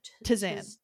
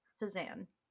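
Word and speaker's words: tisane: It's kind of like Shazam. – Right. – But a little different. – Right. – Tizan. – Tizan. – Because it's spelled with an I. It's T-I-S-A-N-E.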